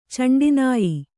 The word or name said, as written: ಚಂಡಿನಾಯಿ